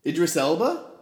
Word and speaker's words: Idris Elba: Idris Albers. – Idris Elba? – Albra.